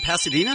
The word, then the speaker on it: Pasadena: Leah in Pasadena.